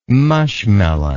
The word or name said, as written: mushmelon